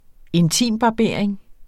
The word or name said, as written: intimbarbering